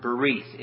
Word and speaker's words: berith: So according to Vine's Expository Dictionary of Biblical Words, the original Hebrew word translated covenant is the word berith. It is pronounced Berith, B-E-R-R-E-E-T-H, emphasis on the second syllable.